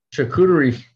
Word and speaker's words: charcuterie: How do you say the word? Charcuterie. – Charcuterie. – Charcuterie. I'm looking over, going, what's the word? Charcuterie. There we go.